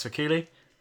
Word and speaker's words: Vakili: You can follow me on Twitter at Alex Vakili. – Try and spell that. – V-A-K-I-L-I.